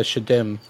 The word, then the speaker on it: Shedim: This type of demon is called a Shedim. – How do you spell that? – S-H-E-D-I-M, which you've probably seen before.